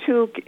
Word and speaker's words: toque: I called it Toque. It's T-O-Q-U-E. Sounds to me like that's not how you pronounce it. And then part two is, what exactly is a toque? – I pronounce it toque, but I think either one is correct.